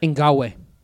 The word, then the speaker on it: Ngakoue: Is it Yannick Ngakoue? – Ngakoue. – That's close.